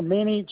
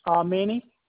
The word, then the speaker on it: Manny: Sure, Manny J. Tavia. – I'm sorry, one more time for me. – Manny J.